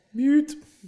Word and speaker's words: Butte: Well, in that case, we're at least the black butt is boot. I don't know how to say it. – Butte. – Butte. – Mm-hmm. That'd be butte, wouldn't it? – I believe so.